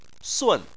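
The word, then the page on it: sun: tone 4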